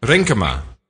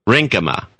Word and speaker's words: Renkema: Spell that for me. – R-E-N-K-E-M-A. – Rinkema. – Rinkema. – Rinkema.